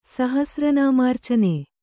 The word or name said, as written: ಸಹಸ್ರನಾಮಾರ್ಚನೆ